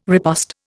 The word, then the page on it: riposte: Indian